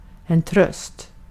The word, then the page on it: tröst: svenska